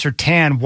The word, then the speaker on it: Surtain: And my question would be, if it's pronounced Surtain, why put the I in the name? That's my question. Just drop the I and just, I mean, if you see S-U-R-T-A-N, you're going to know right away that it's Surtain.